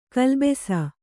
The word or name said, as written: ಗುಲ್ಲೆಬ್ಬಿಸು